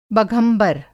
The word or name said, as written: बघंबर